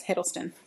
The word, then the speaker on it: Hiddleston: Am I saying it right? – Yes, Hiddleston.